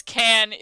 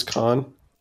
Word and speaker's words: Caan: I appreciate how Hollywood institution James Caan is the dad in it. – You mean James Caan? – James Caan. Yes. I mispronounced it.